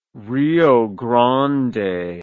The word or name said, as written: Rio Grande